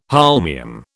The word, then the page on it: holmium: British English